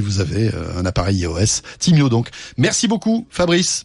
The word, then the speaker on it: Timyo: Timio, ça s'écrit donc T-I-M-Y-O, qui veut dire Time is my own.